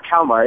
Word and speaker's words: Kalmar: For those who don't know your background, you were born in Is it Kalmar in Sweden? Is that how you pronounce it? – Sweden, yeah, Kalmar, yeah.